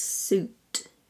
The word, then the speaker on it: suit: If a member of parliament was wearing a posh business suit. Suit. No I in suit.